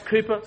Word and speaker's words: Cowper: When the subject of joy comes up, often people will reflect upon the life of a man like William Cowper. It's pronounced Cooper, spelt Cowper.